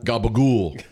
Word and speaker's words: capicola: For the Cotton Bowl, I did my first ever garlic bread sandwich, which was just like ham, capicola. Is that how you pronounce that? Nice. Gabagool.